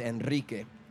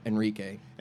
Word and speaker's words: Enrique: My name is Enrique. And I learned at a certain point to be like, it's Enrique.